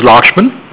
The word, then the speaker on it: Lakshman: My name is Lakshman. – How do you spell that? – L-A-K. – Uh-huh. – S-H-M-A-N.